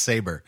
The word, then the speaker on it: Sabro: It's pronounced Saber. It's not.